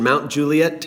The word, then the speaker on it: Mount Juliet: He's also the pastor of teaching and discipleship at Green Hill Church in Mount Juliet, Tennessee. Am I saying that right? – You are saying it right.